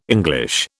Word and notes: English: British English

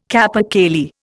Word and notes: capercaillie: Indian

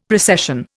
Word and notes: precession: Indian

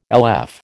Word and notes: LF: American English

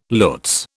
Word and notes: lutz: British English

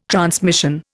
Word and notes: transmission: Indian

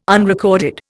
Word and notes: unrecorded: Indian

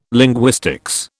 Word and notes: linguistics: British English

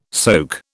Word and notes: soke: British English

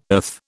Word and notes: earth: British English

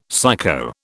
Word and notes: psycho: British English

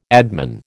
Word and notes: admin: American English

